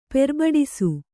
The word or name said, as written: ಪೆರ್ಬಡಿಸು